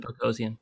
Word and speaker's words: Boghossian: I think his name, I hope I don't pronounce this wrong, Peter Boghossian? – I think you'd pronounce it Boghossian. – Boghossian, I think. – Boghossian, okay. Boghossian, a philosopher from Portland State University.